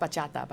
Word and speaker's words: bachata: It's pronounced bachata, by the way.